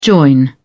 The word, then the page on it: join: British English